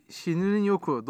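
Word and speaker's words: Shinrin-yoku: Bugün Şinirin Yoku doğru telaffuz ettim değil mi? Evet.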